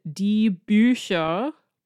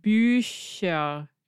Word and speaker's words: Bücher: Das Buch and die Bücher, plural. One book, two books, two plus books. Das Buch, die Bücher. And the Bücher, it's the Ü that makes it a sh sound instead of a ch sound.